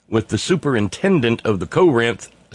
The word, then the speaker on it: Corinth: She'd been sent to Corinth, and if you want to say it correctly like they do down there in Alcorn County, Mississippi, you say Corinth, Corinth. It's kind of actually a double stress syllable.